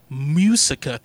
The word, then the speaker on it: Muisca: The origins of El Dorado, the City of Gold, come from legendary tales of the Muisca tribe. – Musinex is pronounced. – Yeah, Muisca. – Muisca. Muisca. The Muisca. M-U-I-S-C-A cool.